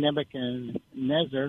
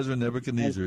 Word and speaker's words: Nebuchadnezzar: There's, of course, King Nebuchadnezzar, or Nezer, I like the way you pronounce it. – It may not be correct, though, but there you go. Nebuchadnezzar, either one.